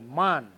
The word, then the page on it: mahn: Mokilese